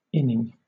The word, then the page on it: inning: British English